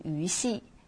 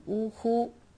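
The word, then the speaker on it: 於戲: 后面这个鱼戏不能读成鱼戏，要读成呜呼。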